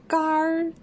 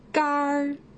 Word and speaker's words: 干儿: 啊，还有一种用法呢，是在它的后面加上儿化音，像干儿，对，这样的发音有点难啊。干儿，干儿，意思是呢，经过加工去掉了水分的食物。